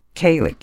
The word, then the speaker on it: Kaling: Mindy Kaling. – Kaling.